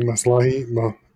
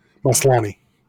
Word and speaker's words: Maslany: Maslahi? I can't ever say her name right. Maslani.